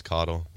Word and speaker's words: Coddle: My last name is Coddle. – Thanks, Gus. – Wait a second. – Yes, it's not spelled the same way. His is K-A-T-H-O-L. This is C-O-D-D-L-E.